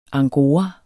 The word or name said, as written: angora